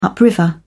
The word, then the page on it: upriver: British English